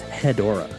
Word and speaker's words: Hedorah: It's spelled like Deborah, so maybe it is Hedorah.